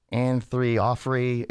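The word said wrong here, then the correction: Orianthi: It's the Michael, whatever her name. – Orianthe. – Anthree Offrey. I wish her name was Anthree Offrey. – Yeah. Orianthe.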